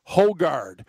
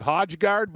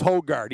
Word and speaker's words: Højgaard: But first round leader, Rasmus Højgaard with the silent J. Rasmus Højgaard. On the FanDuel site, it'll look like Højgaard, but it's Højgaard.